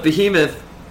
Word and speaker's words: Behemoth: the behemoth...